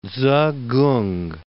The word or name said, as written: Tzu-kung